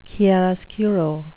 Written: chiaroscuro

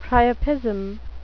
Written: priapism

